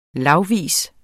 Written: lagvis

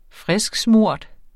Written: frisksmurt

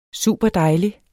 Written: superdejlig

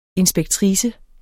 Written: inspektrice

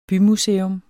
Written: bymuseum

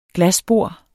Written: glasbord

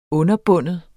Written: underbinde

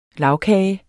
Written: lagkage

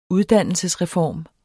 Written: uddannelsesreform

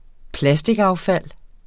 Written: plasticaffald

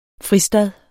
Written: fristad

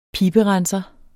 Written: piberenser

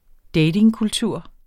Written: datingkultur

